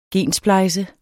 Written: gensplejse

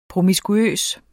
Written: promiskuøs

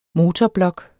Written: motorblok

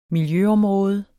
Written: miljøområde

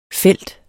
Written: felt

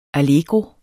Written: allegro

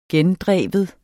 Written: gendrive

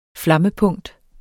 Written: flammepunkt

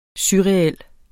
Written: surreal

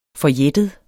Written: forjættet

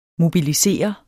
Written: MOBILISEREDES

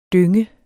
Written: dynge

